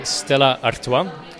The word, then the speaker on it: Stella Artois: We would say Stella Artois in the Dutch-speaking part of Belgium, where Stella Artois is actually from, but people also pronounce it in French, Stella Artois.